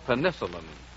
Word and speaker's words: penicillin: I'm sure I could love someone Tell me, how do you pronounce P-E-N-I-C-I-L-L-I-N, that miraculous new drug used for curing infections? You've heard it called penicillin, but the English scientist Dr. Fleming, who discovered it, prefers penicillin, and most authorities have agreed.